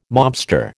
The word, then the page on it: mobster: American English